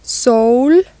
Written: sol

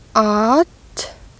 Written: at

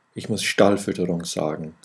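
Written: Stallfütterung

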